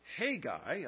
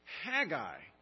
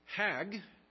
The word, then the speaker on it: Haggai: At one time I thought it was Haggai. I even told that to Karen, but I did some more studying this week, and it's pronounced Haggai. I got the little vowel pointing wrong on that.